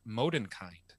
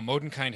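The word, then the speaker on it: Mondenkind: In the original language, Bastion names her Modenkind, which means Moonchild. Now, Modenkind has three syllables, whereas Moonchild only has two.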